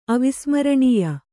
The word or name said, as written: ಅವಿಸ್ಮರಣೀಯ